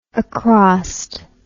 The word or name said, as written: acrost